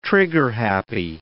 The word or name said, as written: trigger-happy